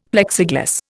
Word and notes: Plexiglas: Indian

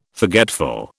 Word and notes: forgetful: British English

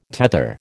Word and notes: tether: American English

